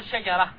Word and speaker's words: شجرة: العرب تقف عليها بالسكون، تقول شجرة، يعني العرب إذا وقفت على التاء المربوطة تقلب التاء المربوطة هاء، لا لاحظ هنا شجرة، ماذا أنطق بالتاء المربوطة؟ ها شجرة رأيت، طيب رأيت شجرة، رأيت شجرة وقفت بالها.